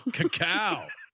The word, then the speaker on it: Cacau: Cacao. C-A-C-A-U.